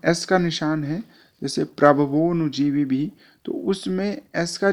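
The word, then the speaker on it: प्रभवोऽनुजीवी: प्रभवोनुजीवी भी अगर संधि विच्छेद कर दिया जाए तो होगा प्रभवः अनुजीवी भी, परंतु यहाँ पर संधि के साथ उच्चारण करना होगा तो उसमें प्रभवो के बाद अ की ध्वनि नहीं आएगी बल्कि सीधे प्रभवोनुजीवी भी इस तरह से उच्चारण होगा।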